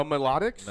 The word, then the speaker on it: Melodics: Isn't it obscure melodic? – Oh, melodics? – Melodics. – Oh, maybe you're right. – Yeah, melodics. Actually, you're right. – Excuse me. M-E-L-O-D-I-C-S.